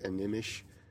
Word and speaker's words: Animish: It's actually pronounced Animish, and it's an Ojibwe word. – Oh. – And it means little dog in Ojibwe. But I call it Animush because the character mispronounces it.